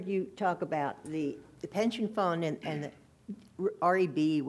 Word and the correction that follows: OPEB: OPEB, that's mm-hmm. how you say it.